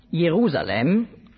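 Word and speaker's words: Jerusalem (ירושלים): Der Name Jerusalem, auf Hebräisch spricht man das aus, Jerusalem, heißt auf Deutsch so viel wie Stadt des Friedens.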